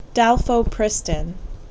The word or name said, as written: Dalfopristin